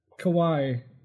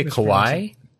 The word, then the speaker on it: Kauai: And Kauai of the Hawaiian Islands. – Do you say Kauai? – Am I mispronouncing that? – I mean, that's cute. – K-A-U-A-I.